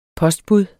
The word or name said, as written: POSTBUDS